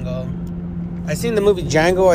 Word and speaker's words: Django: He's like, Django with a D. He's like, and then he turns to him and says, the D is silent.